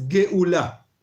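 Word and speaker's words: geula (גאולה): La palabra redención es geula.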